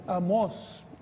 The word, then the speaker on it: Amós: No, no, Amós, tiene acento en la O.